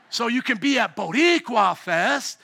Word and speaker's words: Boricua: How do you say it? Boricua Fest? Thank you, my Boricans.